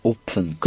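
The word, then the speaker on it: open: 오픈. – 그렇죠. 앞에 오가 있으면요. 되도록이면 오우라고 발음을 해버릇하세요.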